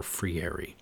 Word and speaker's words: Freire: And a line that I'm drawing from Rousseau to Dewey to Paulo Freire, or Freire in the anglicized pronunciation.